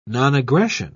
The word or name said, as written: nonaggression